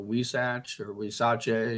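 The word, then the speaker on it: huisache: Also, wisache, if you want to give it the Spanish pronunciation.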